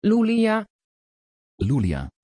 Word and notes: loélya: Dutch

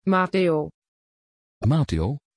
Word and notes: Matthéo: Dutch

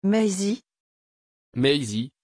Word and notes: Maisie: French

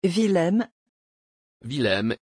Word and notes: villem: French

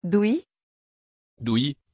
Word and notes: dewey: French

